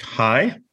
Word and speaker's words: HAI: So they're abbreviated HAI, pronounced HI.